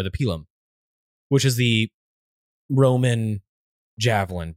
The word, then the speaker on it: pilum: The plumbata and the pilum, or pilum, if you want to pronounce it like a Roman.